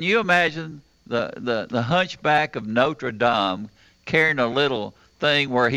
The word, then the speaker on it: Notre Dame: But, you know, the beautiful thing is, Truman, you pronounce that expertly correctly, Notre Dame. – Mm-hmm. – Avon doesn't say it that way.